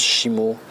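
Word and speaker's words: Shimo (下): quand c'est dans un nom de ville, machin, ça se prononce Shimo, Shimo Itabashi par exemple, ou Shimomura, ou bref, des trucs comme ça.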